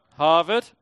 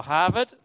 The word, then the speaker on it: Harvard: Um, we had the first college, which was here, which is Harvard, right? Or Harvard, okay? Um, sorry, I pronounced that with an R, but um, there isn't an R really, right? That's just an imaginary R's.